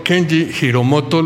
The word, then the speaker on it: Kenji Hiromoto: esperando esta entrevista con nuestro amigo Kenji Hiromoto.